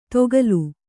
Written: ದೊಗ್ಗಾಲು

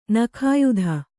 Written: ನಾಗಾಯ್ತ

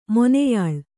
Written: ಮೊನೆಯಾಳು

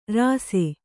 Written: ರಾಸೆ

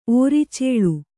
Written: ಓರಿಚೇಳು

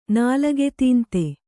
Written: ನಾಲಗೆತೀಂತೆ

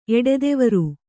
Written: ಎಡೆದೆವರು

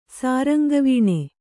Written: ಸಾರಂಗವೀಣೆ

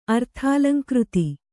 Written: ಅರ್ಥಾಲಂಕೃತಿ

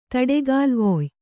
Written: ತಡೆಗಾಲ್ವೊಯ್

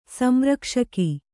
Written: ಸಂರಕ್ಷಕಿ

